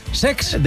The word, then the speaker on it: Cesc: No lo he dicho mal, es Sex.